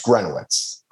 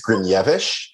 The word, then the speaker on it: Gryniewicz: Sure. It's Grunowitz. – Grunowitz. Oh, that's so much easier than it sounds. – Yeah. Or if you're feeling really saucy, it's Grinevich, but my entire family pronounces it wrong and we're all cool with that.